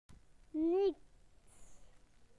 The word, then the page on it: nothing: British English